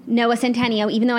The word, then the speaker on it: Centineo: And like Noah Centineo like being on, you know...